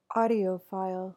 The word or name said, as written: audiophile